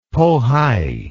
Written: pohai

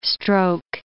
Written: stroke

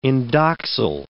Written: indoxyl

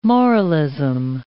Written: moralism

